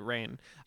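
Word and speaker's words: Reign: R-E-I-G. – Yeah, sorry. I should have pronounced it right. Rain.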